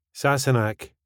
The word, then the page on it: Sassenach: スコットランド英語